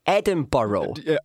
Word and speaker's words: Edinburgh: Wait, sorry, Edinburgh. – Edinburgh. That's how you say it. Come on, James.